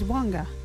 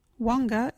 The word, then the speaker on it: wangga: local Wagiri word is wanga. What is wanga? Wanga is talk. You can use wanga in this sentence. My little friends are learning Wagiri wanga. Wanga is spelt W-A-N-G-G-A.